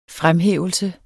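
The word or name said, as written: FREMHÆVELSE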